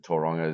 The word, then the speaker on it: Tauranga: It's an odd town to pronounce, but Tauranga is it's rolling off the tongue now. It's good. – Yeah. – I feel it's great. It's a great connection to the place to be able to at least pronounce the name right.